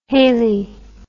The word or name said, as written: haley